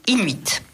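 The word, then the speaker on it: In8: L'écrivain en est Marcus Malte, pardon, et c'est aux éditions Inuit. Dans cette collection, on avait... – Qui s'écrit In et le chiffre 8.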